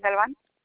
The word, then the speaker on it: Galvan: Natalie Galvan. – Can you spell it for me? – N-A-T-A-L-I-E and then G-A-L-V-A-N.